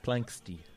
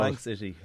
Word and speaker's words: Planxty: Yeah, I suppose formed out of the ashes of Planxty, which is a name I couldn't pronounce until recently. Planxty. – I can. – What did you used to call Plank City? – Plank City.